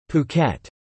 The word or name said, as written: Phuket